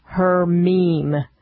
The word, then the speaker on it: Hermine: I found out the absolute, unequivocally correct pronunciation of the storm that's bringing rain over the state of Texas is Hermine.